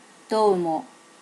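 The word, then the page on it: domo: native Japanese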